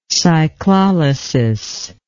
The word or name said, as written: cyclolysis